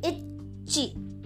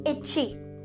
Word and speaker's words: ichi: Two syllables said with me, ichi. Okay, now said fully, ichi. Okay, ichi.